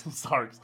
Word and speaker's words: Sarx Bear: But probably the top Stardew Valley streamer in a S-A-R-X-B-E-A-R. Sarx Bear, right? – Yep. If you pronounce it Sarah, you can say Sarah Bear, but there's an X between.